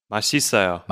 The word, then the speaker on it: 맛있어요: So many of my foreign friends who are learning Korean like yourself, they say 맛 있어요, but how should you pronounce it naturally again? – 맛있어요.